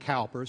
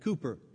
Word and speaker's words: Cowper: It's not Cowper, C-O-W-P-E-R. He lived in Olney, England, where they pronounced it Cooper.